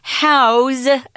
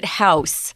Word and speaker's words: house: Now, notice here we're using house as a verb. Don't pronounce it house. It's if it's a verb, to say this is where you're going to put something, it's house with a Z.